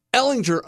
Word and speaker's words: Ehlinger: Now listen, Ehlinger, I like Ehlinger. I don't even know how to say his name, but whatever. – I think you're saying it right.